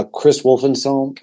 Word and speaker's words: Chris Wolstenholme: Chris Wolfen, uh, I gotta say it right. Uh, Chris Wolstenholme, home, I think from Muse.